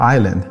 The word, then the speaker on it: island: It was my turn to read it in class, and I pronounced the word Iceland instead of island.